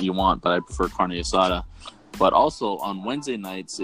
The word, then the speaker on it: carne asada: Carne asada.